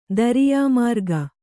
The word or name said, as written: ದರಿಯಾಮಾರ್ಗ